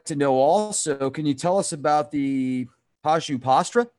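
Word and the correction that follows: Pashupatastra: Did I say that properly? – Pashupati Astra. Pashupati Astra. – Thank you.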